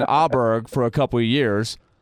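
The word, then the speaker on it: Åberg: Now, Pontus's last name, if you really get technical in Swedish, it's something like, and the Swedish guys laugh when you even try to say it right. They say something like Auberg, Auberg, or something like that. But the Swedish guys usually say, go with the Americanized pronunciation. But now on top of that, Pontus himself, when you asked Pontus, he said he would like to be called Auberg this year.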